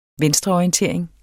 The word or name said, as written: venstreorientering